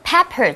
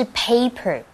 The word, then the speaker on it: pepper: 对啊，因为这个 pepper, 注意发音哦，不是 paper, paper 是纸 pepper 是胡椒 black pepper 是黑胡椒而 white pepper 呢则是白胡椒的意思。不过啊，我们刚刚讲说就是你喜欢的那个对象啊，在英文当中直接用 crush 这个字就好了。